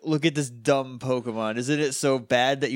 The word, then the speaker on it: PKMN: I think it's supposed to be pronounced as Pokemon. It's just a shorthand for writing it.